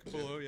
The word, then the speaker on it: Capullo: Greg Capullo, yeah.